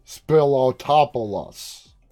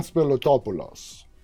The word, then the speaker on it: Spilotopoulos: If I try to say it, using my American accent, which is not an accent, it's how I talk, I would say it and I would pronounce it like Spilotopoulos. Spilotopoulos. But no, it's Evan Spilotopoulos.